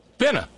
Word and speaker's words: finna: Would you please pronounce it correctly for me? – Finna. Finna.